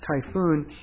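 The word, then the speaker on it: Typhon: Now, it's only spelled with one O, but it's pronounced Typhoon.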